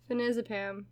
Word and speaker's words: phenazepam: That's I, I, I said it how they spelled it. Finazepam. – Yeah, I'm assuming that's how you say it. Finazepam. Yeah, that sounds right. That sounds better.